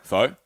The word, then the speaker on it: pho: And they would bring out like, okay, so it's pronounced like pho or pho. Pho? Pho? – Oh, pho. – Pho, yeah. – Basically. – So it's pho.